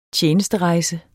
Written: TJENESTEREJSE

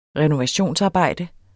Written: renovationsarbejde

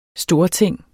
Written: STORTINGENES

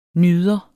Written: NYDERE

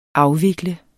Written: afvikle

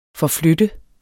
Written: forflytte

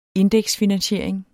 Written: indeksfinansiering

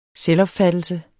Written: selvopfattelse

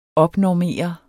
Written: opnormere

